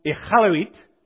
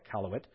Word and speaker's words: Iqaluit: It's pronounced Iqaluit, but just call it Iqaluit.